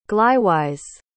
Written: glywys

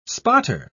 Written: spotter

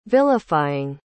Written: vilifying

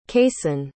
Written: kaysen

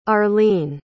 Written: arleene

